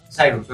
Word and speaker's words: Cyrus: Cyrus, ok.